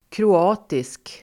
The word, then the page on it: kroatisk: [kro'a:tisk]